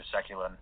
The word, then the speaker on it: Sekulin: I haven't found a Spencer Sekulin anywhere, so it should be pretty easy to find.